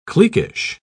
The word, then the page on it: cliquish: British English